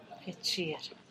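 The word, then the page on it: hechir: Teotitlán del Valle Zapotec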